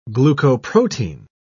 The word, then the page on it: glucoprotein: British English